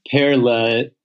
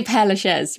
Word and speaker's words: Père Lachaise: So, Père Lachaise, how do you pronounce it? My French is horrible. – I say Père Lachaise, but...